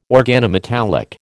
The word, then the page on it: organometallic: American English